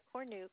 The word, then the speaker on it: Cornuke: Teresa Cornuke, C-O-R-N-U-K-E.